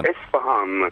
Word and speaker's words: Isfahan: La pronuncia esatta è Isfahan, ecco.